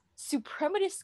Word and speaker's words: Suprematist: Suprematist composition 1916.